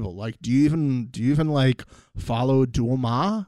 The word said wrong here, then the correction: DeuxMoi: Did I say it right, Mixie? DeuxMoi. Duoma. – DeuxMoi.